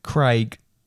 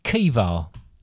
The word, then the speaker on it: Caoimhe: C-A-O-I-M-H-E. How would you pronounce that? – C-A-O-I-M-H-E. – Yep. – This one's a hard one. Craig. – Craig? It's actually pronounced Kiva. – Kiva. – Or Kiva. – Kiva. – Yeah. How the hell do you get that from that? – I think it's more is look, you're pronouncing things in an English way.